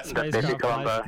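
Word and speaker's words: BepiColombo: I'm pretty sure I pronounced that incorrectly. – Yeah, Bepi Colombo.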